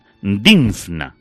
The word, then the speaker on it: Dinfna: a ver si lo digo bien, Dinfna, NFN, madre mía, Santa Emelia y San José Marello.